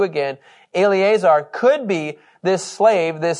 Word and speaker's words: Eleazar: Eleazar? – Sounds great.